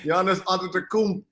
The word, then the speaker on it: Giannis Antetokounmpo: Giannis Antetokounmpo, and it's Bo, not Ho.